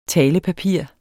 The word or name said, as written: talepapir